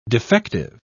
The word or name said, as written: defective